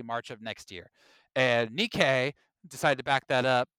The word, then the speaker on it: Nikkei: And this week, literally this week, the newest report came from Nikki, how do you say that, Will? – Nikkei, thank you. Nikkei, I like that.